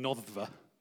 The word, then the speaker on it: Noddfa: We decided to give our house a name, and we gave it the name Noddfa, which is spelled N-O-D-D-F-A.